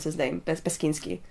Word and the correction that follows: Beksiński: Beksiński, yeah.